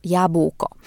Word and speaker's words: jabłko: Na początku powiedziałam Wam, że wyjaśnię, jak poprawnie wymawiać słowo jabłko. Ja przez cały ten odcinek właśnie tak mówię, jabłko, ale może powinnam mówić po prostu jabłko.